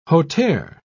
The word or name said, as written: hauteur